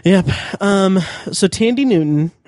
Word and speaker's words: Thandie: Thandie Newton, who apparently her name is pronounced Tandy. – Tandy Newton.